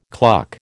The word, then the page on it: cluck: American English